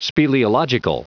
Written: speleological

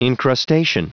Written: incrustation

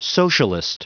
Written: socialist

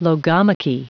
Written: logomachy

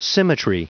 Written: symmetry

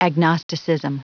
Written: agnosticism

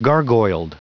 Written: gargoyled

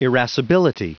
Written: irascibility

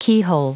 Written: keyhole

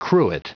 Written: cruet

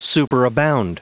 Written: superabound

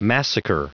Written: massacre

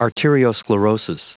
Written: arteriosclerosis

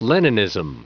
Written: leninism

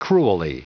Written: cruelly

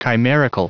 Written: chimerical

